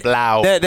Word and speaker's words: Blough: B-L-O-U-G-H. – I pronounce it Blau.